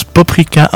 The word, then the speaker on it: paprika: dont je rappelle on prononce paprika en hongrois.